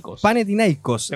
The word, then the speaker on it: Panathinaikos: También el, de, el Villarreal de Ben Britton, 3 0 al Panatiani. ¿Cómo se dice eso? Panathinaikos.